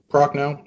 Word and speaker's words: Prochnow: Is that how you say it, Prochnow? – Yeah, Prochnow.